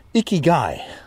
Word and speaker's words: Ikigai: I was listening to an audiobook about ikagi. Am I saying that correctly? It's this Japanese uh, art of living and working. Let me go double check that. I've already reviewed part of the book Ikigai.